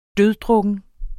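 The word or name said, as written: DØDDRUKKEN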